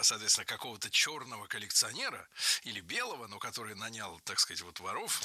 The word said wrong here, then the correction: нанял: Нанял. Неверно. Правильно, нанял. Ударение в этом слове падает на первый слог во всех формах прошедшего времени, кроме женского рода. Он нанял, оно наняло, они наняли, но она наняла.